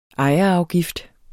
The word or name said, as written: ejerafgift